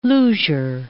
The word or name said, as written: Luger